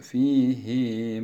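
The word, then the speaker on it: fîhî (فِيهِۦ): Yudâ'af lehul azâbu yevmel kıyâmeti ve yahlud fîhî muhânâ. Normal şartlarda fihi muhana olması gereken fihi muhana şeklinde okuyoruz. Buradaki zamirin uzatılmasının bir başka gerekçesini de şöyle izah edilmiştir.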